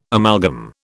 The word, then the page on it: amalgam: British English